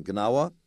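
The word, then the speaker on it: Gnawa: And you write about the African-American artists becoming, and if I say this wrong, you'll correct my pronunciation, Ganawa, right? – Right. Yeah, you say it perfectly.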